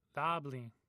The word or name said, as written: thabthin